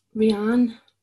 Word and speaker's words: Rhiannon: Is it Rhiannon? – Rhiannon.